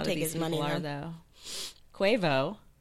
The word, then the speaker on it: Quavo: Yeah, it's Quavo. – Oh, okay, Quavo.